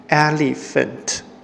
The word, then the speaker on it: elephant: 好，e 的发音是 a，le 是 li，ph 发音是 f，还有 ant 发音是 ant。所以连在一起就是 elephant，e l e p h a n t，elephant。